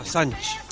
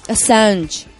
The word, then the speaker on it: Assange: Assange. – Assange.